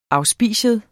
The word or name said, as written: auspicium